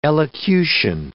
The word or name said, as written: elocution